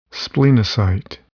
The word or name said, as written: splenocyte